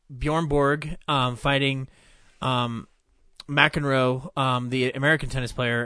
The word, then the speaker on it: McEnroe: saw Borg versus McEnroe. McEnroe, sorry, didn't say it right.